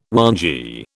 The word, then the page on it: whangee: British English